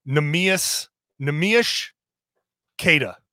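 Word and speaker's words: Neemias Queta: Nemeas Keita. – All right. – Portugal has the SH sound at the end of, instead of the S, they have a sh. So it's Nemeas Keita.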